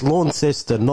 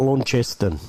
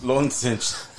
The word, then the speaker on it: Launceston: The only complaint I have is that it's Launceston, not Launceston. – Launceston.